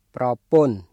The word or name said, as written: ប្រពន្ធ